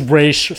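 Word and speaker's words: Ra's: Get Roz on the phone. – Where's Roz? Where's Raish? Sorry. – Oh, that's right. Sorry. Got to do my pronunciations properly. – Raish Al Ghul.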